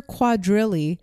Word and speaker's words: quadrille: They were mildly successful and had 3 albums, Meet the Simon Sisters, Cuddlebug, and the Simon Sisters sing the Lobster Quadrille and other songs for children. – What? What does lobster quadrille? – I don't know. I probably pronounced it wrong. It was Q-U-A-D-R-I-L-L-E. – Eh, close enough.